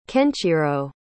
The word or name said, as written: kenchiro